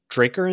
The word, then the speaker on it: Drucquer: So it's from, I believe it's pronounced Draker and Sons.